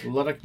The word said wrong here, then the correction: LDUK: Music, I guess? – No, I think it's all capital letters, so you pronounce it. LDUK? – Yeah, LDUK.